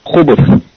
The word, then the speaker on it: khubuth: khubuth, the plural of khabith. But some of the scholars said that this word should be pronounced khubuth, khubuth, not khubuth.